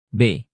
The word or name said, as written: b